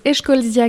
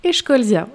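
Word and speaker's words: Eschscholzia: se prononce Escholzia californica.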